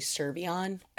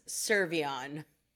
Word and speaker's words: Cervellon: My last name is actually Cervellon. – Oh my. But it's okay. Yeah, I'm trying to pronounce it. It's the typical European error. Sorry about that. – Honestly, it's absolutely fine. I just really, nobody knows how to pronounce it. So I just always take that opportunity to make sure everybody knows what it is. So to the audience, Jess Cervellon.